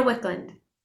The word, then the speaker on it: Wicklund: Yeah, Hannah Wicklund.